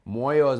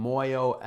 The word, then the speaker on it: Moyo: Moyo is my last name, M-U-O-I-O. Mm. Moyo, M-O-Y-O, it means heart and soul in Swahili, and at the same time, it's the exact pronunciation of my last name, so it just worked out.